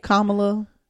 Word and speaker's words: Kamala: Kamala. – Say it right. – Kamala and Jill.